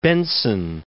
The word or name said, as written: Benson